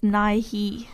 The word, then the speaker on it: Nye: How is that Nye? – How would you say it? – I would assume it's Nye-he. – Well, it could be. I'm saying Nye, like with a Y at the end.